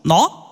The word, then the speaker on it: nu: Das wird geschrieben NU, Nordpol Ulrich, und es wird so ausgesprochen. No? Also man könnte verstehen, nein.